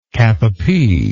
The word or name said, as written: capapie